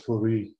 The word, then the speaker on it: Fourie: Yes, it's Tertius, and my surname is Fourie, yes.